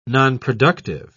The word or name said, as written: nonproductive